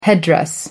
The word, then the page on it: headdress: American English